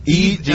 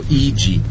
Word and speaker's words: Idzi: Ichi. Ah, bueno, se pronuncia así. I-D-Z-I hace el sonido Ichi. Ichi. D-Z es G en polaco.